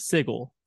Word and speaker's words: Sigil: And one thing I think, like, when it comes to the city of Sigil, which is spelled S-I-G-I-L, which is an actual word in the English language, which is pronounced sigil.